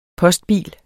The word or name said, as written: postbil